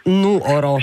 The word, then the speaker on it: Nuoro: Ho imparato che l'accento va sulla O. Nuoro, sì, ecco, perché l'ho sempre sbagliato.